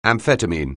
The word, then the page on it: amphetamine: anglais